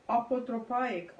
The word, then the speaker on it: apotropaic: The way you pronounce it, is there's no H there. – So how would you pronounce it? – Well, apotropaic, I would say.